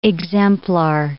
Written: exemplar

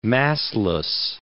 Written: massless

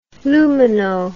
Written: lumino

